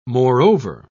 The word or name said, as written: moreover